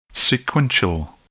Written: sequential

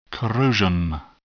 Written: corrosion

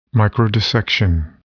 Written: microdissection

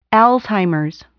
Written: Alzheimer's

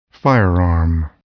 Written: firearm

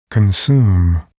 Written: consume

